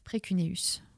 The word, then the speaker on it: précuneus: Comment ça s'écrit euh, le terme Alors P-R-E-C-U-N-E-U-S, e e précuneus.